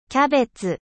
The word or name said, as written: chou